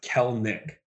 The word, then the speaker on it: Kelenic: But by the way, it's pronounced Kelenic. – Kelenic? – Yes. – Kelenic. – Yes. – Huh. Where'd you hear that? Now I'm going to my pronunciation guide right now. – Read it in a story and seen it before. Mm-hmm. In fact, one of the stories written about him couple years ago. It even had to, it says pronounced K-E-L-L hyphen Nick.